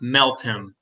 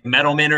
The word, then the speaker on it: Meltem: pronounced Meltem. I know I always want to say Metalman or something, but I've heard it's Meltem.